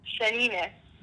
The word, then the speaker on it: shanina: So we call it in Arabic, the actual word is shanina. – How do you say it? – So shanina.